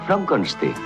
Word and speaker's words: Frankenstein: Frankenstein. Se pronuncia Frankenstein.